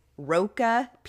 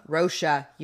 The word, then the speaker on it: Rocha: So Laci Roca Peterson. – Oh, I love that name. – I hope I'm pronouncing it right. – Rocha? Don't come at us. Rocha? – Rocha.